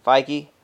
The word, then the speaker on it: Feige: Feige? Feige.